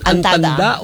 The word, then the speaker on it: antanda: Alin yung tama dun, Mark? Ang dami ko nang binigkas. Antada, antanda o... Ano yun? Antanda o antada? – Antanda. – Isang letra lang yung diferensya.